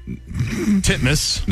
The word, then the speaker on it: Titmus: Pronounce it the way it's spelled. – Ariane Titmus. Titmus. – There you go. – Is that how you say it? – That is legitimately how you say it.